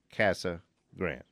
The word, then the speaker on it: Casa Grande: That said, everyone, in capital letters, in the Valley and in Casa Grande pronounce it the old Anglo way, Casa Grande.